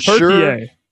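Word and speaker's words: Türkiye: It's Turkey A instead of Turkey.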